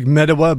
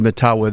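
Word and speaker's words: Mettawa: Looks like Metawa, but I think they pronounce it Matawa there.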